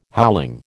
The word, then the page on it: howling: American English